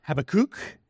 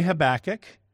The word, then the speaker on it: Habakkuk: Now, technically, if you wanted to say this like a Hebrew, his name is Habakkuk. That just sounds kooky to me. So I'm going to say Habakkuk. I'm not going to pronounce it like a Hebrew would.